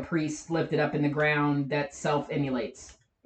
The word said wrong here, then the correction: immolates: em- immolates is how it's actually pronounced